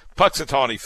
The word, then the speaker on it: Punxsutawney: You know, Punxsutawney Phil, is that how you say it? Is that how you say it, Steve? – Yes.